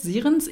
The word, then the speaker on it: Sirens: Jetzt frage ich mich gerade, wie man Sirens ausspricht. Sirens? Ich weiß es nicht genau. – Nee, es sind Sirenen. Also im Deutschen sind es Sirenen und im Englischen hast du schon richtig gesagt.